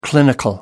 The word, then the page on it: clinical: American English